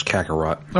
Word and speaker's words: Kakarot: Is that how you say his name? I don't know how you say his name. – Yes, Kakarot.